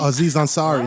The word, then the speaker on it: Aziz Ansari: And you even see that with the um Aziz and Zeri. Did I pronounce his name right? Aziz and Zeri. – Aziz Ansari. What? Situation Aziz Ansari. Aziz Ansari.